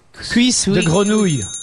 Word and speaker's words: cuisse de grenouille: cuisse de grenouille.